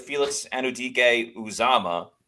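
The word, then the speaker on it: Felix Anudike-Uwazurike: But yeah, you mentioned with Felix Anudike Uzama.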